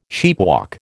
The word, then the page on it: sheepwalk: American English